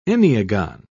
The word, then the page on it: enneagon: British English